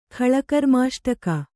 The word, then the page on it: ಖಳಕರ್ಮಾಷ್ಟಕ: Kannada